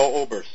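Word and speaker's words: Obrst: First of all, it's Leo Oberst, so yeah. The pronunciation is Oberst.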